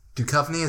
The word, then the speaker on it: Duchovny: I'm more familiar with that, with David Duchovny, is that how you say his name? – That is how you say his name.